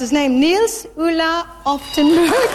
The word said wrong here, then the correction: Oftebro: And the winner was Nils Ulla Oftebro.